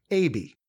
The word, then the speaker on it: Aebi: His real name was Bob, and the last name, I'll spell it first, A-E-B-I. So it's only four letters. There's no L in it, and it's pronounced A-B. So Bob A-B.